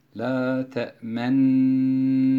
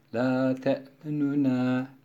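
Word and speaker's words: la te'menna (لا تأمنا): Bir de bu kelime ihtilas ile okunur. La te'menuna, la te'menuna, nuna, nuna şeklinde. La te'menuna, bu şekildeki okuyuşa ihtilas. La te'menna şeklindeki okuyuşa da işmam demiş oluyoruz.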